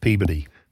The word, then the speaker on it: Peabody: We lived in Wakefield and Peabody. My mom's family was from Lowell. – Peabody. What? – Peabody. It's spelled Peabody, but it's pronounced Peabody.